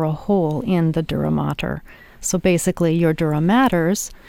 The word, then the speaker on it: dura mater: It's spelled D-U-R-A, dura, mater, M-A-T-E-R, so that's the play on words, mater, maters.